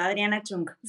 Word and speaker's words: Adriana Chung: ¿Le, pronuncié bien el nombre, Adri? Sí, está perfecto, Adriana Chung.